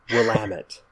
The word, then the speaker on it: Willamette: It's Willamette. – Oh. – Willamette Valley, not Willamette.